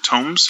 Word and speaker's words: Tomes: T-O-M-E-S, I've heard you. – I usually pronounce it Tomes. – Tomes, okay, got you. Yeah, like, and it's a play on words. So tomb, a place where people are buried, and tome is a large book, typically.